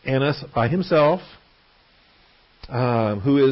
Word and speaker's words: Annas: Point one, only the Gospel of John records a preliminary hearing before Annas, or Hannas, if you want to put an H in front of it, or Hanas, if you want to really give it a Hebrew pronunciation.